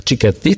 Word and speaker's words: Cicchetti: I Pietro Cicchetti, jeśli dobrze wymawiam jego nazwisko. Cicchetti, tak? – Tak.